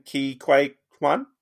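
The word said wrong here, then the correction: Ke Huy Quan: Kei Hui Kwan. – Kei Hui Kwan. Apologies.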